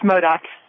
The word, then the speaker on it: Modoc: But first, is that Modoc County? How do you say that? – It's Modoc.